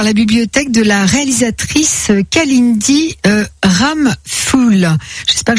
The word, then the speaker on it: Kalindi Ramphul: Je crois que c'est Kalindi Ramphul, ouais. – Ah, c'est ça. Bon, bah, j'ai bien prononcé.